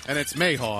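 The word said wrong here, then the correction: Mahan: How do you say your last name, Mike? – It's M-A-H-A-N. Mayhan.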